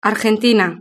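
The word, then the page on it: argentina: Spain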